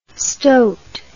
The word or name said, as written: stoit